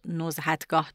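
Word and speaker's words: نزهتگاه: توی داستان اشتباه تلفظ شد نزحتگاه درسته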